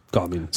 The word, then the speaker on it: Garmin: Garmin. – Sagen wir Garmin.